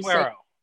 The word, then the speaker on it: Suero: I'm not going to butcher your last name, so I'll let you Suero.